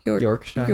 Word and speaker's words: Yorkshire: Yorkshire.